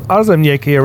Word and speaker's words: Aarzemnieki: ESC Radio live from Copenhagen, and we are here with the guys from Aarzemnieki representing Latvia with the song Cake to Bake.